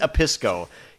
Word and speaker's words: Obispo: It's Obispo.